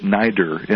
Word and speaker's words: NIDRR: They pronounce it NIDR, N-I-D-R-R.